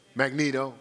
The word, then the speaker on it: Magneto: It was a Magnesio? Magneto. Magneto. Magneto. I say it wrong every time I use the example.